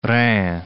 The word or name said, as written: Rhin